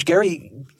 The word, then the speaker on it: Gerry: I suppose if you're Elbridge Gerry, you know better that this is at least right. you know it's named after me, but it's not completely pronounced that way.